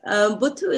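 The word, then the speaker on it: Botho: When is the word Buthu used? Am I saying it right? – Yes, you're pronouncing it very well. – I've learned something. – Buthu is used quite a lot, and particularly when we are trying to bring us back together as a community.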